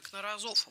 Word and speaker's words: Кнорозов: Это Юрий Валентинович Кнорозов. Вот так он ставил ударение на свою фамилию.